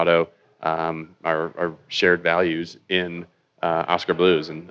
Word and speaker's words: Oskar Blues: Oskar Blues.